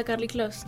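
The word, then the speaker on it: Karlie Kloss: Y lo he visto muy poco, no lo he visto en Kerly... ¿Cómo se dice? – Ah, Carly Close.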